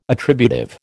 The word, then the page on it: attributive: American English